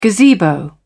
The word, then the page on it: gazebo: British English